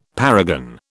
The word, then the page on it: paragon: British English